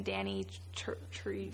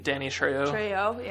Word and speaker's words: Trejo: I did like that, I don't know how to pronounce his last name, Danny Trejo. – Trejo, yeah.